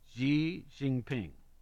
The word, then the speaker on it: Xi Jinping: The Chinese president, and I always have trouble with these pronunciations, it's X-I-G. J-I-M-P-I-N-G. So Jinping. – Mm-hmm. – Xi Jinping